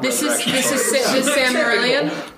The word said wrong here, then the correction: Silmarillion: Is that the Silmarillion? – Yeah, it's the Silmarillion, which you should read.